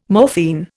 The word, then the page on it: morphine: Indian